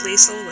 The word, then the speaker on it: Leisel: My family says Leisel with a soft S, and occasionally people say it with a hard S, and it sounds really cool to me, but definitely use a soft S, I guess, because that's what it really is, I suppose.